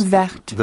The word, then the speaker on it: Vecht: Vecht. – The Vecht River.